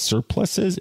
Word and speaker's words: surplice: How do you pronounce it? Surplice. So like the other word, surplices,